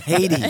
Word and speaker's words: Hayti: It's Haiti. – I always drive by that because it's spelled H-A-Y-T-I, right? – Yes.